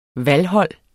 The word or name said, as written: valghold